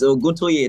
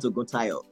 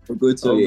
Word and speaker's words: Oguntoye: You know, the amazing thing is that you are getting the second name wrong on this podcast. It's Oguntoye, not Oguntayo.